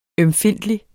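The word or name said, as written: ØMFINDTLIG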